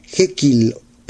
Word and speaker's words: Jekyll: Jekyll se escribe J-E-K de kilo, Y-L-L. Jekyll, pero se pronuncia Jekyll. Y Mr. es una M-R punto. Hyde H-I-D-E.